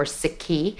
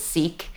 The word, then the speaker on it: Sikh: Oftentimes in the US and Canada, we hear it pronounced as Sikh and Sikhism. Mm. We're trying to decolonize language, Stu. I'm sure you're all about decolonizing language and say things properly. So it's actually, I am a Sikh.